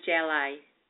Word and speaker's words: Gjelaj: So my name is Diane Gjelaj.